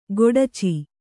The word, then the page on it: ಗೊಡಚಿ: goḍaci